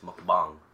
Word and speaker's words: mukbang: Then my other Korean friend is like, you're an idiot. It's mukbang. We're clearly Asian. We have the short A sound.